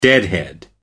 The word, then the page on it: deadhead: American English